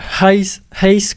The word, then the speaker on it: Heis: Anaïs Volpé a réalisé d'ailleurs le film Hays Chronique, ça s'écrit H-E-I-S, ça veut rien dire.